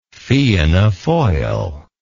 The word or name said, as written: Fianna Fail